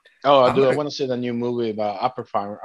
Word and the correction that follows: Oppenheimer: I can't even pronounce his name. About the director that created the nuclear bomb. – Oppenheimer? – Oppenheimer, thank you.